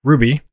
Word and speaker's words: RWBY: I'm a little light this week, but straight from Wikipedia, in case you're listening to this episode and have no idea what Ruby, spelled R-W-B-Y and pronounced Ruby, is about.